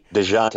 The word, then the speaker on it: Dejounte: DeJounte, I got you. – DeJounte Murray, and Patty Mills plus Aldridge.